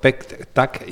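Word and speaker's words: PET-TAC: El hospital cuenta con los equipos, aquí lo tengo apuntado, PEC-TAC y SPEC-TAC. No lo he dicho bien, lo pronuncias tú. – Sí, perfecto.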